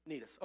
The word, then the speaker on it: Cnidus: Jumping down to verse 7, it says, we had several days of slow sailing, and after great difficulty, we finally neared Cnidus. It really is pronounced that way, by the way. You're saying, where's the sea? Cnidus.